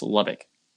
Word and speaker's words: Lubbock: The second syllable is a schwa, so it's just Lubbock.